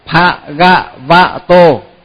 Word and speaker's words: Bhagavato: Cái chữ đó là nhiều người hay đọc nhầm lắm. Nhiều khi là Bhagavato, tức là đọc thật ấy. Mà quen rồi bây giờ cứ đọc hoài ấy. Mà phải phát âm cho thật đúng là Bhagavato.